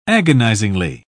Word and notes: agonisingly: British English

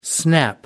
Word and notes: snap back: American English

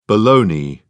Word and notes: bologna: British English

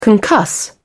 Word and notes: concuss: British English

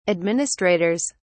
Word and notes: administrators: British English

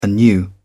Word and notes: anew: British English

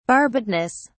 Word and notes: barbedness: British English

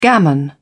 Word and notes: gammon: British English

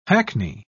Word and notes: hackney: British English

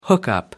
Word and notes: hookup: British English

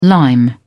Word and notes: lime: British English

